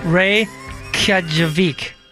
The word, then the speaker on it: Reykjavik: It says, hi, I'm Icelandic. That's wrong. It's pronounced Reykjavik.